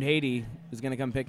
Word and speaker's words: Hayde: No, it's like H-A-Y-D-E, not Haiti. – Oh, shit. So it's not even Heidi. – Yeah. It's Haiti.